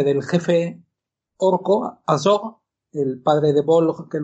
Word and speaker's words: Azog: Pero, cuidado, nos reímos, esto parece una tontería, pero es sumamente importante este hecho, el hecho de que exista la palabra Azog, azog, correctamente pronunciado, eh, escrito en la frente del enano Throg.